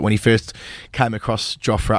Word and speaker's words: Jofra: I think Jofra is the pronunciation that we're going with, actually. Jofra. I think it's hard to go away from Jofra when you've said it as often as we have, but Jofra, as a young lad, he was a leg-spinning wicketkeeper.